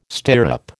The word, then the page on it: stirrup: American English